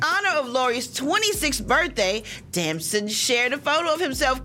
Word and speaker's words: Damson: So from turtle to Nunu, word is Lori Harvey is now dating the star of Snowfall, handsome British actor Damson Idris. Did I say it right here, crack? Is it Damson or Damson? – Damson. – Damson.